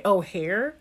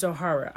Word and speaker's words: O'Hara: I always want to say O'Hare, but it's O'Hara, I believe.